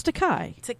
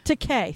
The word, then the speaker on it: Takei: Am I saying Takei right? It's Takei. – Takei.